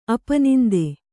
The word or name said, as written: ಅಪನಿಂದೆ